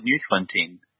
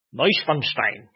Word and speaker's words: Neuschwanstein: Might as well build Neuschwanstein. I butchered that pronunciation, didn't I? – Neuschwanstein.